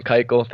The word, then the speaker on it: Keuchel: Former Astro Dallas Keuchel, or however you pronounce Keuchel. – His name. Keuchel, thank you.